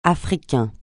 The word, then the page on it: Africain: francais